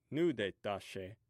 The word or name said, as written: nudetashe